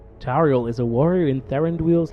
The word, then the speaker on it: Tauriel: I have said that right, Tauriel. – Yes. Yeah, I believe so, Tauriel.